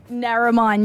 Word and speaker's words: Narromine: Narromine, New South Wales, which was where I first played cricket.